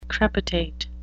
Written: crepitate

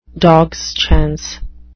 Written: dogs chance

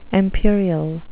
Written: empyreal

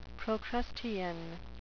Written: procrustean

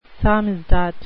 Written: samizdat

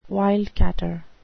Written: wildcatter

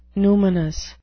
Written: numinous

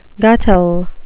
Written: guttle

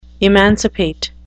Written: emancipate

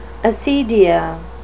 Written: acedia

